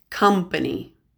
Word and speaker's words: company: This next short list of words also has a stressed COM, but the O is pronounced as a short U, so it's pronounced comfort, company, compass.